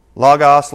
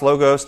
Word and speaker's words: Logos: Now, the Word, Logos, Logos, depending on how you like to pronounce it.